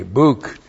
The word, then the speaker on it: buk: That's B long U K, buk.